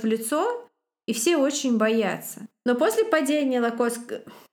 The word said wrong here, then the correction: Локотская: Я опять говорю Локотской республики, она Локотская, поэтому я прошу прощения, она Локотская, ударение на первую О, говорю это в основном себе.